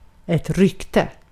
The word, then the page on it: name: /neɪm/